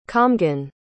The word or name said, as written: comhghan